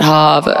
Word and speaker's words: Harvard: I think you mispronounced it, Harvard.